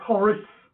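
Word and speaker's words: charis: The original Greek word for grace is spelled C-H-A-R-I-S. C-H-A-R-I-S. And it's pronounced horis.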